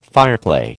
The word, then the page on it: fireclay: American English